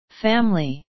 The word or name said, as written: family